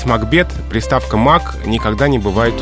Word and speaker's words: Макбет: И вот именно в этом произведении по традиции закрепилось произношение «Макбет» с ударением на первом слоге.